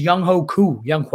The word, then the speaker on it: Younghoe Koo: Last but not least, Younghoe Koo is how you pronounce it.